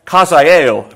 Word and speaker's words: Hazael: And the king said to Hazael, take a present in thine hand, Kazael, if you want to tell it the way it's pronounced,